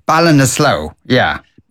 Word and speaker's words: Ballinasloe: Well, how did you say your town, Ballinasloe? – Ballinasloe, yeah.